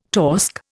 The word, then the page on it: torsk: Indian